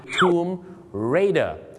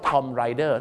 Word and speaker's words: Tomb Raider: Tomb Raider แต่บางคนนะครับไปออกเสียงว่า Tom Rider ถ้า Tom Rider ก็ผู้หญิงขี่ Tom ถ้า Tomb Raider ก็ผู้หญิง